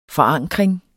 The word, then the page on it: forankring: [ fʌˈɑŋˀgʁeŋ ]